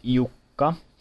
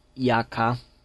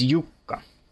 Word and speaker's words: Jukka: Yes, the name is actually pronounced Jukka, though some of you may think of it as Jaka. I don't hold it against you, but you're dead wrong. It is Jukka. There's a U and a K. It is spelled with 2 K's, after all.